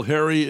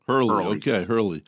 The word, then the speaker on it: Herrle: Mr. Bill Herrle is on the line with us today. Am I pronouncing your name correctly, Bill? – No, it's Herrle, Mr. Mayor. – Herrle.